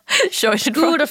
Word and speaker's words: Schadenfreude: It's Schadenfreude. How do you say that? – Schadenfreude.